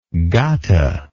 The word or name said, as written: gatha